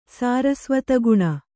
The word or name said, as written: ಸಾರಸ್ವತಗುಣ